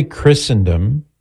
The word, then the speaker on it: Christendom: So we say Christendom with a silent T because some words in English have a silent T, like Christmas.